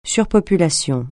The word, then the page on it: surpopulation: French